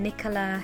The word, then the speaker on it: Nicholla: Look up my name, Nicholla Henderson Hall. My first name is spelled N-I-C-H-O-L-L-A.